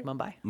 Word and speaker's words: Mumbai: Mumbai, did I say that right? – Mumbai. – Mumbai.